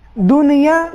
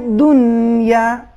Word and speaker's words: دنیا: آیت نمبر 16 اس میں کلمہ دنیا استعمال ہوا ہے، اس میں بہت توجہ ہے، کلمہ دنیا میں حرف نون ساکن ہے، اس پر کسی قسم کی حرکت یا قلقلہ نہیں آنا چاہیے، دنیا یہ صحیح نہیں ہے، صحیح تلفظ ہے دنیا، نون بالکل ساکن رہے گا، اس میں کشش اضافی بھی نہیں ہے، دنیا.